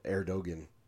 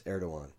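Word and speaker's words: Erdoğan: I always pronounce his name wrong, Erdogan, but it's Erdogan.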